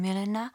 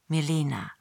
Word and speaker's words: Milena: Genau wie ein fotografisches Bild von Milena hätschelt Kafka förmlich ihren tschechischen Namen, den er jedoch als Deutscher am liebsten mit der Betonung auf der zweiten Silbe, Milena, nicht in der tschechischen Weise Milena, aussprechen möchte.